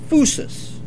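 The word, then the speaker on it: phusis (φύσις): The Greek word is physis, but it's pronounced phusis. I don't understand all about the Greek, but it's pronounced phusis.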